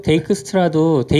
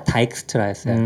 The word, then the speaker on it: 데이크스트라: 데이크스트라도 데이크스트라라고 쓰기 시작한 지 얼마 안 됐어요. 옛날엔 다 다이크스트라였어요.